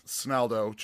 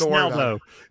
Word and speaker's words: Snaldo: So it's there kind of this weird double-edged sword of, like, they're like Snally, Snella, Snallydo, Snackydo. Like, I'm like, Snaldo, just call me Snally, it's fine. Like, it kind of, over time, people just remember your name from, yeah, you know.